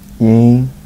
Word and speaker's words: Yeen: Yeen. – How you spell it? – Y-E-E-N. – Y-E-E-N.